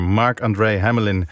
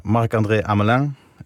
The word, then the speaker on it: Marc-André Hamelin: En zijn naam wordt dus op zijn Frans uitgesproken. Ja, ik hoor het heel veel mensen inderdaad zeggen. Marc-André Hamelin.